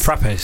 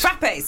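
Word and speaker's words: frappé: How do you say that coffee that begins with F? Frappes. – Frappes. – Frappes, that's it.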